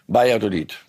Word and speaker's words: Valladolid: Jetzt wollen wir Tore sehen und wir wollen natürlich Lewandowski Tore sehen gegen Valladolid. Valladolid. So spricht man es korrekt aus.